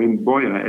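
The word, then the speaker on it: imbuia: Imbuya? – No, not at all. No, that's an interesting thing. Imbuya, or imboya, as we would call it. Imboya, yep. I-M-B-U-I-A, I believe it is spelled.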